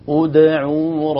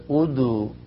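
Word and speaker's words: ادعوا: فنقول أدعوا ربكم وليس أدو أدو، لا أدعوا ربكم.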